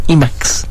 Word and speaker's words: Emacs: Se pronuncia Emacs. E-M-A-C-S.